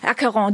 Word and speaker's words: Acheron: Und Bernhard Schrammeck hat heute eine neue Aufnahme des französischen Ensembles Lacqueron dabei. Lacqueron, ich habe es vorhin falsch gesagt, Lacqueron, weil sich so schreibt, aber Acheron bezieht sich auf den griechischen Fluss.